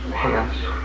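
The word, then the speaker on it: haunt: But if you were saying it, you would say it was H-A-N-T with a short A.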